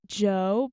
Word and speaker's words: Djo: So this next one is called Delicia by Joe, but it's spelled D-J-O.